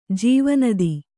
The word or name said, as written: ಜೀವನದಿ